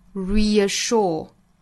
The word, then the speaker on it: reassure: 所以在这个词里面，其实相当于两个元音相遇了。那我们在发音的时候，其实还是会带出一个小小的 ye 的发音，由前面 re 这个音节带出来相近口型的一个辅音 ye。 Reassure，